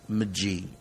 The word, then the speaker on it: Magie: Their last name is spelled Maggie, M-A-G-I-E, but pronounced McGee or McGee.